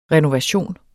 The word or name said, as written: RENOVATIONER